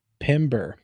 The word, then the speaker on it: Pember: UNC Asheville has a very good player here in Pimble, who's been very good for them. Drew Pimble. Pimbler. Pumble. Still not saying it right. P-E-M-B-E-R. Pimber. There we go, like timber.